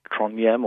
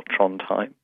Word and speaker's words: Trondheim: And that's where I spent most of this recent holiday, although I had wanted to go up to see a place called Trondheim or Trondheim, depending on your pronunciation,